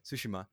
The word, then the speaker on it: Tsushima: Thank you for reminding me, because I saw it too, and I was like, okay, it's pronounced like this, Tsushima.